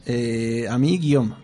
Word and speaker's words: Guillaume: Primero presento a Guillem Loens. – Ahí está, sí. Eh, ¿Sí? ¿Lo dije bien? – Sí, Guillaume. Se dice Guillaume porque, bueno, el nombre es francés y por eso tiene esa pronunciación.